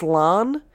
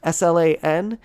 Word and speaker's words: Slán: It's an Irish green t-shirt with on the front, it is the word, I believe it's pronounced slán, Slan, which is a Gaelic word for goodbye.